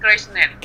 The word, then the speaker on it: Kirchner: ¿Cómo se dice? – ¿Cuál? – El apellido de Néstor, Kirchner. – Kirchner.